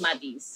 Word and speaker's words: Maty's: Yeah, and I wasn't sure if I was saying it right. I was saying Maddie's, but it's more. – Well, now it's turning to Maddie's, but for me, it's Maddie's.